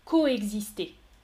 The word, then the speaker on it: coexister: Je répète, écoutez bien coexister, coexister. C'est facile. Vous entendez normalement, hein, regardez l'orthographe, C-O-E-X-I-S-T-E-R, il y a deux lettres séparées, deux sons différents. Je prononce donc numéro 2, coexister.